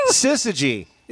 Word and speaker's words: syzygy: You're really close. Okay. – Sizigi. – There you go. Sizigi. The word is Sizigi.